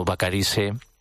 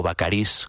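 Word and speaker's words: Bacarisse: como Vacarisse, o Vacarís, como ustedes quieran pronunciar el apellido de este compositor,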